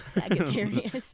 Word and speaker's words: Sagittarius: That's nice. The hard G. – Yes. – Sagittarius.